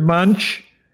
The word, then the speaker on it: Munch: His name is Munch, M-U-N-C-H, Munch, it's pronounced in English.